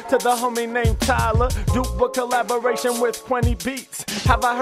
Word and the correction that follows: Kenny Beats: heard of Kenny Beats? Of course he brings the heat. I mispronounced his name, so I had to correct it.